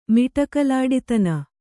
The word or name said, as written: ಮಿಟಕಲಾಡಿತನ